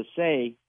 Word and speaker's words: assay: But I was wondering if you knew where I could find a biobarcode assay, or I don't, it's A-S-S-A-Y.